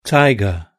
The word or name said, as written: tiger